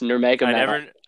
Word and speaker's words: Nurmagomedov: Khabib Nurmagomedov was undefeated. – Is that how you pronounce it? – Yes, Nurmagomedov.